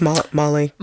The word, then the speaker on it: Mawle: So Joseph Mauly, is that how you say his name? He's the guy from Game of Thrones. – I think it's Mauly. – Mauly? – Yeah. Okay. – It's spelled M-A-W-L-E.